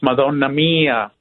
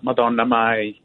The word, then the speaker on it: Madonna mia: It's Madonna Mia, not Madonna Mai.